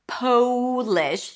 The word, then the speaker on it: Polish: Then we want to make sure that the O is pronounced with a long O sound. Polish. The trick is to add the W.